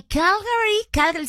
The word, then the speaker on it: Calgary: Desde Calgary, sí lo dije bien, Calgary, Canadá.